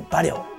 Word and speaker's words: 馬良: 馬良のことですね馬に良い馬に良いと書いて馬良ご存知ですかこれは馬氏ってますね馬馬って名字ですね馬馬氏 5 人の兄弟がいましてその中で馬良